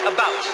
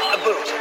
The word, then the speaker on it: about: I speak English and French, not American, and I pronounce it about, not a boot.